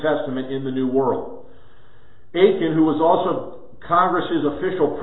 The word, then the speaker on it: Aitken: So, it was Robert Aitken, how do we say this? – Aitken. – It was Robert Aitken of Scotland,